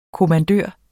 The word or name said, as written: kommandør